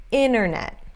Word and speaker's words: internet: Isso acontece, esse T que basicamente some, desaparece, acontece quando tem NT em uma sílaba átona, uma sílaba fraca, ok? Internet. Internet. Internet. Ok? A sílaba tônica é essa primeira.